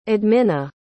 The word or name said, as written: admina